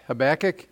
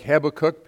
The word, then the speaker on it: Habakkuk: It's right after Micah, and it's just for, I've heard it pronounced Habakkuk and Habakkuk. I like kind of like Habakkuk because I want someone to cook my food.